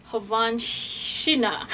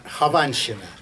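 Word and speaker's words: Khovanshchina: This opera, it's an introduction to the opera by Mussorgsky, Havanshina. – Very good. Very good. Havanshina.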